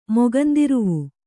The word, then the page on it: ಮೊಗಂದಿರುವು: Kannada